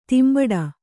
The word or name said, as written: ದಂಬಡಿ